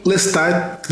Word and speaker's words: Lestat: E aí, Lestat na verdade é Lestat, né? Porque ele pede para pronunciar tudo, mas eu falo Lestat porque é um nome afrancesado. Então vocês me perdoem, mas muito difícil pronunciar com o T.